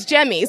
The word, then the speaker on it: Jaime: I love it because my name spelled is Jamie, but yes, pronounced as Jemmy, so I feel ya.